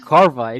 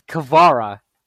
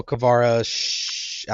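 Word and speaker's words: Kvara: Carva Shalati or something like that. I forget. – You already said it's not Carva. It's Kavara. – Yeah, see, I already said it wrong. There you go. Well, Kavara, I don't remember.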